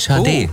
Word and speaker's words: Sade: Geschrieben werden sie S-A-D-E. Und das hören Sie da immer so, Sade, Shade. Das wäre tatsächlich richtig, Shade.